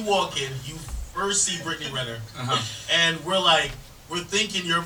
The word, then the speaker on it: Brittany Renner: Brittany Renner.